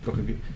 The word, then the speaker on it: Prokofiev: Tal vez de Debussy, Prokofiev, no sé si está bien pronunciado. – Prokofiev. – Sí.